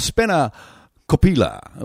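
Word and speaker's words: spanakopita: And it's pronounced something along the lines of Spena Kopila, which is a Greek spinach and feta pie with Suzza's special salad and a beautiful raw winter bread as well, and it's delicious, folks.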